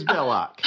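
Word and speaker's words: Belloq: Belloc? Is it Belloc? I don't even know if I'm saying it right. – It is Belloc.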